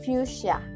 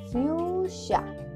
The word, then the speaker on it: fuchsia: El color fucsia, fucsia en español, en inglés se pronuncia fuchsia. Repite, fuchsia, fuchsia.